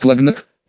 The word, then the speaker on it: Клагнак: «Это Борис Клагнак», — представила она мне нового товарища по несчастью, произнеся его имя на непередаваемо французский манер, с ударением на первое «о».